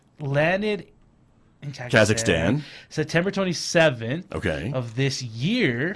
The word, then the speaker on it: Kazakhstan: Kazakhstan? – Kazakhstan.